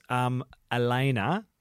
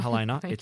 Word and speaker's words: Elena: It was Elena, silent H. – Yeah. – Just remember that, okay? It's not Helena, Elena, just for those playing along at home, because I got confused.